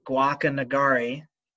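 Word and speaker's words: Guacanagarí: The chief in the area, who I'm definitely going to mispronounce his name, Guacanagari. Sounded pretty good. – Nailed it.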